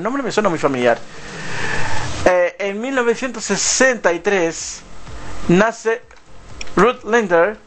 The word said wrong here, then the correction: Lemper: que es una cantante alemana. O sea, Ruth Lemper, para ser más justo en la pronunciación.